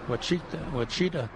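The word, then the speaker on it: Ouachita: And a name I can't pronounce, Quachita. Wachita. – Wachita.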